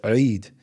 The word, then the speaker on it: Eid: And then afterwards, we have what's called Eid, E-I-D, is how they usually spell it. It's a letter in Arabic called Ayn that doesn't exist in English. So we always have to figure out how do we say this, but it's Eid. And so most of the time you'll see people say Eid, so that kind of sound.